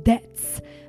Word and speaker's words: debts: Careful with the pronunciation debts. The T is silent, debt.